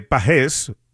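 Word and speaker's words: Pagés: Y si escuchaste a José Mota y escuchas que dice de Pages, es que así es, así es que realmente se pronuncia Andy Pages, pero obviamente Andy pues ha dejado que le llamen Pages porque ya es de costumbre, pero correctamente es como lo está anunciando y pronunciando José Mota, Pages, que le está yendo muy bien a Andy Pages en esta temporada.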